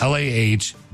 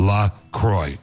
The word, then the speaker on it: La Croix: So officially it's pronounced LAH-CROY. So it rhymes with enjoy. It's like La Croix and joy. – La Croix. – It's not, yeah, it's not La Croix or La Croix, like the French would say.